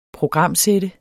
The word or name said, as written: programsætte